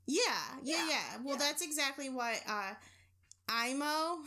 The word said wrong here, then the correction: Aimo: Nope, I said it wrong. I even wrote down phonetically how to say it. Aimo Koivunen did. – Aimo Koivunen. – Koivunen. But it's spelled A-I-M-O.